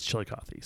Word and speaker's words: Chillicothe: I've heard that's how you pronounce it. Some say Chillicothe, but I've told by the locals it's Chillicothe.